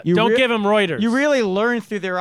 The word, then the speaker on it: Reuters: Well, th- next story of the week comes to us from Re- Roy- how do you say it? Reuters. Reuters. Readers. It's Reuters. It's spelled Reuters.